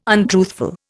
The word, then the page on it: untruthful: Indian